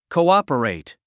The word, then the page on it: cooperate: kouάpərèit; コウアパレイト